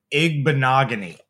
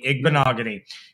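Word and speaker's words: Igbinoghene: Noah Igbinogheni. Okay. Rhymes with mahogany. Igbinogheni.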